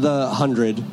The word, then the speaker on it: The 100: Uh, but but tell me about so you're working on the 100. Yes. Is that am I saying that correctly? That is correct. Yes. Okay.